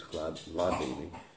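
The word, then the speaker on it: Hladini: H-L-A, you know, it's pronounced Kladi, Ladini. Not exactly Ladini, it's Kladini.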